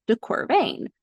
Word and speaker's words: de Quervain: And so first aid remembers or has a mnemonic for this, is pain rhymes with de Quervain.